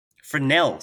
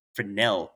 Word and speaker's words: Fresnel: So apparently I've been pronouncing it wrong. So it's Fresnel, because Augustine Jean Fresnel is French, and that's how the French say it. So the S is silent. So yeah, it's Fresnel lens.